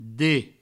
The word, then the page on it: det: Provençal